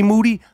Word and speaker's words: Moody: Me, G Moody. Last name rhymes with duty.